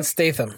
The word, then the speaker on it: Statham: Am I saying his name right? – Jason Statham. – Statham, there the you go.